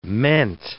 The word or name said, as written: meant